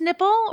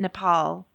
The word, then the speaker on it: Nepal: PJ Byrne, Professor Warren, is it pronounced Nipple or is it Nepal? – It's Nepal, damn it. It is Nepal.